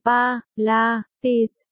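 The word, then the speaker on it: Pilates: 7, Pilet, đọc là Pa, La, Tis,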